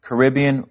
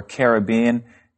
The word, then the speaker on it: Caribbean: Well, as you said earlier, Vicki, this word can be pronounced two ways, Caribbean or Caribbean, and it's the body of water that's between North and South America.